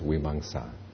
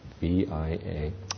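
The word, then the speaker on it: vīmaṃsā: And the fourth one, translated as discrimination or analysis, is vimamsa. Vimamsa. And the second M has a dot over it, which is means just translated, it's pronounced like an NG. It's vimamsa.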